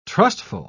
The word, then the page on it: trustful: British English